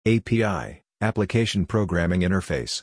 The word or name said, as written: API (Application Programming Interface)